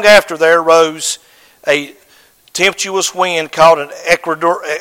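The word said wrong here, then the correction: Euroclydon: I promise you, at home, I learn how to pronounce these things, and I get to church, and I cannot pronounce them. Eurocladon. Yes, thank you.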